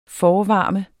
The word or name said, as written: forvarme